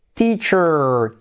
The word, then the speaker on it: teacher: 98, profe, teacher, ti. Se escribe con E, se pronuncia como I, teacher, teacher. Acabado en letra clave, R, teacher, teacher.